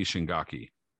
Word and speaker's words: Shingaki: was against a guy named Satoshi Shingake. I'm totally butchering his name here. – Yeah, Satoshi Shingaki. – Shingaki, totally.